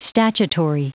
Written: statutory

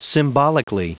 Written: symbolically

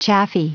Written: chaffy

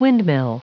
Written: windmill

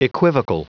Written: equivocal

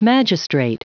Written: magistrate

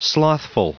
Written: slothful

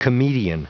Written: comedian

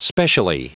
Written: specially

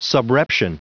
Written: subreption

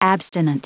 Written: abstinent

